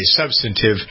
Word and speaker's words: substantive: We have very substantive, that's a word, by the way, and that's the way to pronounce it, in case you didn't know. Substantive. Some people say substantive, but if you check it out in Funkin' Wagnalls, you'll see it's substantive.